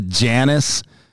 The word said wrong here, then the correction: Janis: So, so it's Janis. So oh, Janis. – Janis, Janis is of Latvian Latvian descent.